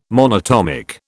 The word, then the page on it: monatomic: British English